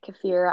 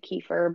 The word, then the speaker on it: kefir: So I pronounce it kefir. I don't know if some people might pronounce it kefir, but it's K-E-I, sorry, K-E-F-I-R.